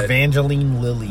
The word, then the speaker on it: Evangeline Lilly: Evangeline Lilly.